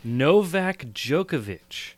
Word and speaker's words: Novak Djokovic: But the thing that I think is most interesting for me to jump off is, I should have prepared how to say his name. Novak Djokovic. – You got it. That is exactly how you pronounce it.